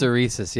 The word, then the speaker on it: hysteresis: And I'm hopefully not going to end up butchering this term. But what this measures is the hysteresis. I think that's the correct way of pronouncing it. Hysteresis, yeah. I looked it up, yeah.